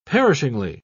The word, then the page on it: perishingly: British English